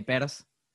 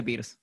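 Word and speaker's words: Peirce: Además, eh, quiero decir que este PERS ¿Sabes que se pronuncia Pierce? Todo el mundo le dice Pierce. Eh, ¿En serio? Sí, sí, se escribe Pierce.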